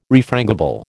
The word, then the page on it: refrangible: American English